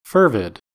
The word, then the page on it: fervid: American English